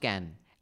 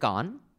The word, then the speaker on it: Cannes: I did not know that rat's pronunciation, but when I did go to Can, everyone in Can pronounced it Can. – and because till then. Been... Hmm. Till then, even I used to pronounce it Khan like most Indians.